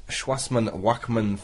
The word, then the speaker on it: Shoemaker-Levy: And you can hear more about Comet Shoemaker-Levy 3 in...